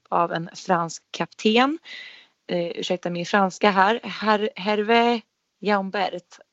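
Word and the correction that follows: Hervé Jaubert: Och Hervé Jobert. – Ja, det var bättre.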